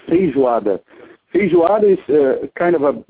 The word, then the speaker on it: feijoada: We call it feijoada.